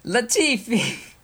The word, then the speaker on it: Latifi: Latvia. – What? Who are you? – It's L-A-T. I don't... – Latifi!